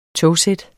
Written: togsæt

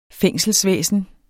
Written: fængselsvæsen

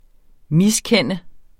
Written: MISKENDENDE

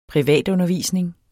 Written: privatundervisning

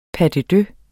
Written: pas

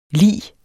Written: lig

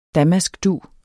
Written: damaskdug

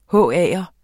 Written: HA'er

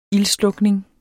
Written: ildslukning

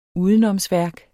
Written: udenomsværk

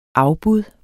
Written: afbud

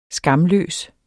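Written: SKAMLØS

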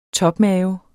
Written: topmave

